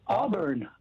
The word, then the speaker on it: Auburn: In Arbor, California. Am I saying it right? – Auburn. Auburn. – Yeah, oh, Auburn. Oh, okay.